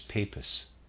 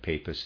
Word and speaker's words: Papus: Papus, as he calls himself, readers aside, I'm pronouncing that Papus, it's P-A-P-U-S, it could be Papus. I like the sound of Papus, end of readers aside.